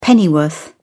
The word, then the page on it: pennyworth: British English